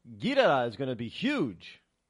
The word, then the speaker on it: Ghidorah: King Ghidorah. That's how we pronounce it. But if you listen to the Japanese, it's Ghidorah, King Ghidorah.